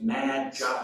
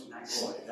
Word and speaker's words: Magoi: I'm trying to help you learn not to use Magi. Use Magoi. That's the Greek. So I can say, do what? You're not saying that right. It's Magi.